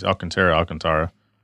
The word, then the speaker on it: Alcantara: The door panels have been rewrapped with Alcantara. I think that's how you say it. – Yeah. Tara. You hear it both ways. Alcantara.